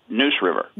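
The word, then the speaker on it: Neuse: am I saying this right? Noosey? Is that how it's? – Noose River. – Noose River, and one of the first riverkeepers in the Southeast.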